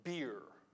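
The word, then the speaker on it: bier: It's B-I-E-R. It's pronounced beer, beer. Yeah, not the beer you drink, okay? But same pronunciation, different meaning, okay?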